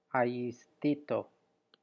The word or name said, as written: ayiasthto